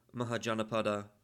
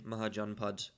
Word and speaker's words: Mahajanapada: Some people say Mahajanapada, some people say Mahajanpad. The difference seems to be that the first of those is the Sanskrit version and the second is the Hindi version.